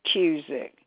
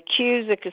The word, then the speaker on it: Cusick: It's Rebecca McCurdy Cusick. And the Cusick is spelled just like music, but it's a C instead of an M.